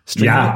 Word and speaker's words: Streamlit: Streamlit.